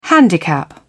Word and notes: handicap: British English

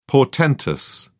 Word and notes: portentous: British English

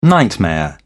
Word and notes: nightmare: British English

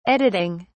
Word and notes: editing: British English

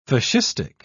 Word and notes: fascistic: British English

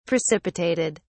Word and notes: precipitated: British English

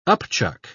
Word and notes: upchuck: British English

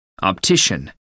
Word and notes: optician: American English

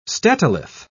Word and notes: statolith: British English

